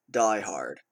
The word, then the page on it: diehard: American English